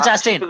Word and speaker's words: Chastain: That's her real name is Jessica Chastain or Kastain. I don't know how to pronounce that. – Okay. – Jessica Chastain.